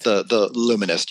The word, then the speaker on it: The Luminist: So one is called, your first was The Illuminist, right? The Luminist. – The Luminist, yeah.